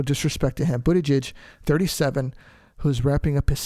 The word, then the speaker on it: Buttigieg: His name is Pete Buttigieg,